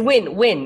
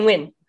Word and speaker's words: Nguyen: It's win, as in win or lose.